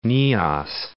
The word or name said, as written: Nias